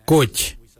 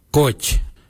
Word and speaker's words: goodj: Garli is spelled G-I-I-L-Y-I, garli. For the traditional stone axe, we say gotch, gotch. Gotch is spelled G-O-O-D-J, gotch.